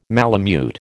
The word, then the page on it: malamute: American English